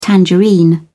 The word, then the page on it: tangerine: British English